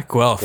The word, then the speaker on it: Guelph: Is that how you pronounce it? Guelph? – Guelph? Yeah, Guelph.